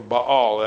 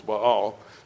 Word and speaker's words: Baal: And then you have Baal, or Baal, actually. It's really two syllables, Baal.